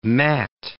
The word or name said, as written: matte